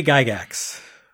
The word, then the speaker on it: Gygax: Hard G. – Gygax? – Jerry Gygax.